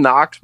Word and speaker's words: Knox: Kevin Knox, by the way, on this pronunciation guide is all capital N-O-C-K-S. As if somebody needs to know how to pronounce Knox, K-N-O-X. – Any other good names on there? – No. Josh Okogie is O-H-K-O-G-E-E.